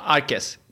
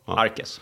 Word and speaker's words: Arquez: Arquez. Q, precis som Nordqvist, Söderqvist. Uttalas K. Arquez.